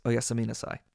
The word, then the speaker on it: oyasumi nasai: But if you want to do it more formally or more politely, it's oyasumi nasai.